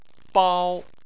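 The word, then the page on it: bao: tone 1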